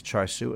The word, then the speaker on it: char siu: it's like Mumbai of pork, and this is how they say it in in Cantonese is char siu, and I think it's chow sha in, in if Mandarin or whatever.